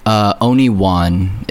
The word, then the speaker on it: Oniwan: Oniwan. It's basically spelled like Obi-Wan, like Obi-Wan Kenobi. – Mm-hmm. – But sub the B for an N and delete Kenobi, and you'll find me.